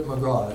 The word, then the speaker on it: McGraw: McGraw, he pronounces it McGraw, it looks like McGraw.